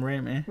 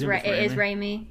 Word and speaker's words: Raimi: Could you tell me how to pronounce his last name? – Sam Raimi. – It is Raimi, yeah. – Yeah, I presume so. No one, every time I've ever heard it, it's always been Raimi.